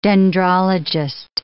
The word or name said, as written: dendrologist